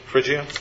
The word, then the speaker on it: Phrygia: Am I pronouncing it? Phrygia? – Yeah.